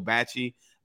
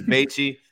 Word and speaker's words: Bachie: We then go back and look at this past week where Joe, I hope I'm not saying his his name incorrectly, but I call him Joe Bachie. – I don't know if it's, I don't, I, don't, know if it's Batchy. – Um, it looks like it will be pronounced Batchy.